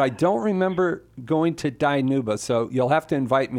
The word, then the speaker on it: Dinuba: Danielle in Danuba, welcome. – Hi, can you hear me? – I can. Where is Danuba? – It's actually pronounced Dinuba.